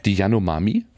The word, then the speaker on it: Yanomami: Habe ich es falsch gemacht? Die Yanomami? – Ja, das war richtig.